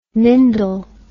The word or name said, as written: mindel